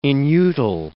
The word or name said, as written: inutile